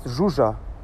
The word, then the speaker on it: Zsuzsa: Hey Richard, ah, ich war gerade laufen und habe die Geschichte angehört und äh, die, Bulgar, die ungarische äh, Schachgroßmeisterin heißt Zsuzsa, ja? so wie Jacques oder Jean, heißt sie einfach Zsuzsa, nicht zu sein.